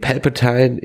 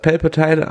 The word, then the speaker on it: Palpatine: Und war es nicht sogar das Palpatine an der Padawan? – Sag mal bitte Palpatine. Du sagst immer Palpatine, aber sag mal bitte Palpatine. So wird er ausgesprochen.